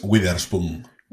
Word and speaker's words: Witherspoon: Em, es Witherspoon.